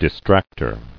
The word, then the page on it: Distracter: dis·tract·er